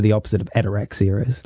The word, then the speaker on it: Ataraxia: It is called Ataraxia. – That's right. – I hope I'm saying that right. – Yeah, you are. Ataraxia.